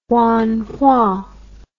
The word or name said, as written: Huanghua